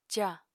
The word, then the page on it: ca: apatani